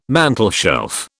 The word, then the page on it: mantelshelf: British English